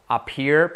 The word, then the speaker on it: appear: Si acaso la pronunciación de appear, como here, beer, near, appear, la P también aspirada, appear, se parece a up here.